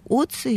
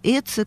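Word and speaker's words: Ötzi: Так вот, если говорить непосредственно про археологические раскопки, то, конечно, очень интересный пример – это Оцы или Эцы, как его у нас произносят, найденный в горах Тироля в начале 90-х годов.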